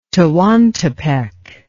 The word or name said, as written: Tehuantepec